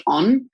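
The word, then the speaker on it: Ohne: So I've been using this CBD body balm from a brand called On. And the way it's spelt is very different to the way it's pronounced. So it's O-H-N-E, but it's got like...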